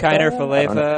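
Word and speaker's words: Kiner-Falefa: For Texas, is it Kiner Falefa? – Kiner Falefa, I think.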